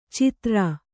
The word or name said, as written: ಛಿದ್ರ